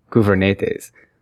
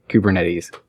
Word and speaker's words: Kubernetes: I think I met a Greek gentleman who said the actual Greek word is pronounced something like Kubernetes, but it's something that was birthed on the West Coast of the United States, so we pretty much say Kubernetes.